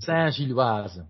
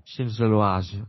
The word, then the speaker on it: Saint-Gilloise: Ik weet niet eens hoe ik het erachter moet uitspreken. – Saint-Gilloise. Saint-Gilloise, oké.